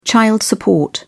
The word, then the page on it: child support: British English